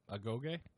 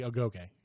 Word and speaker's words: Agoge: The, that's it? How you pronounce it. – Agoge? – Yes. The Agoge.